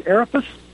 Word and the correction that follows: Arbutus: The Arbutus. – Arbutus. Okay.